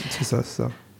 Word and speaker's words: boccia: Donc on passe au deuxième sport qui est la boccia, je crois que ça se prononce comme ça. – C'est ça. Donc, exactement.